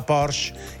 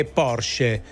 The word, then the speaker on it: Porsche: Prima noi dicevamo la Porsche, invece è Porsche, così si dice.